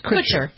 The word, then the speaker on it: Kutcher: In the video that is out now, Kushner, is that how you pronounce it? It's Kutcher. – Kutcher.